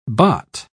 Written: baht

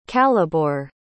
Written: calibor

